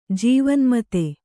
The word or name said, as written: ಜೀವನ್ಮತ